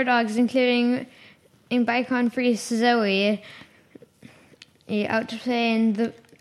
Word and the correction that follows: Bichon Frise: It's a Bichon Frise. I think that's how you pronounce that.